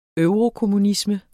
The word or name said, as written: eurokommunisme